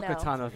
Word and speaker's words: Ahsoka Tano: Asoka Tano, that's the right way to pronounce it? – Oh, no, it's hard. It's Ahsoka Tano. – Ahsoka Tano, thank you. – That's okay. Everyone mispronounces it.